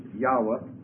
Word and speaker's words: Yahweh: This is Yahweh, Y-A-H-W-E-H. This would be the Hebrew pronunciation.